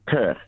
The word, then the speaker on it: Kirk: Kurt.